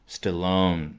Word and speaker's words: Stallone: The Rocky films，关键是你要用英文，哪怕简简单单的和老外聊几句，你会发现上来可能就受挫了。为什么？他名字你说不溜 Sylvester Stallone，特别是他姓氏的发音啊，重音是在第二个音节，Stallone。 Stallone，那你最起码也多念几遍才能够熟悉这个名字吧？